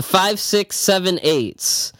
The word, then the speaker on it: The 5, 6, 7, 8s: Okay, next up we have the 5, 6, 7s, and 8s. That is that's not how you say it. – See, five, six, seven, eight. The 5, 6, 7, 8s.